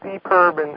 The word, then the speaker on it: EPIRB: So you really were prepared, but your parents made you take a locator beacon called an EPIRB. – Yeah, it's pronounced EPIRB,